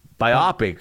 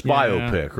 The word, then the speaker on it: biopic: Yeah, so we rhymed it with myopic. – But it's biopic, right.